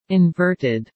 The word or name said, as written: inverted